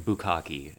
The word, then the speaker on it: bukkake: I had to describe what, well, my very conservative stepmother, I had to read the term pixelated bukkake. And, or she said, bookcake. So we had to correct her pronunciation and at least steer her in the vicinity of what that word meant.